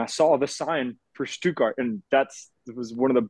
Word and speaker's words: Stuttgart: It was always Stuttgart to me. You know, we pronounced it much differently in the south of the USA. There's a lot of German culture, German heritage there, so I knew that properly it was Stuttgart.